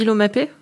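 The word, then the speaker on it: Laumape: Il y a peut-être un truc qui a pu aussi euh, les, remettre psychologiquement, le, recrutement annoncé de, de l'OMAP, on dit l'OMAP oui. – On prononce comme ça.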